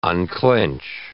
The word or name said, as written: unclench